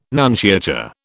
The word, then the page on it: nunciature: British English